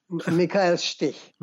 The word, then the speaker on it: Michael Stich: Michael Stich.